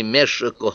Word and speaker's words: Мешико: Например, в силу того, что в испанском языке отсутствуют вовсе или произносятся иначе некоторые характерные для Нуатль звуки, само название нашей страны Мешико вы произносите то как Мессика, то как Мексика, то как Мехико.